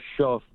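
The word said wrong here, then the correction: Shough: whatever his name is, Shaw, whatever you pronounce shuck. – It. – Shucking oysters. Shuck.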